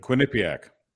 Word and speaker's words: Quinnipiac: I can never pronounce the poll. What is Quinnipiac? How do you? – Quinnipiac.